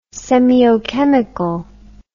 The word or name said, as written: semiochemical